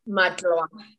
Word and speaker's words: Matlwa: Matlwa.